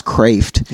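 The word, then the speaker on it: Kreeft: But, like, for lay people such as myself, Peter Kraft, it's pronounced Kraft. – Yeah, yeah. – It means crab.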